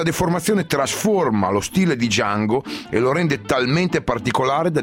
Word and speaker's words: Django: Django. – Sai come si scrive? – D-J-A-N-G-O.